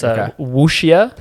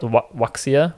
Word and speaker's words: wuxia: It's wuxia. – Okay. – Spelled wuxia.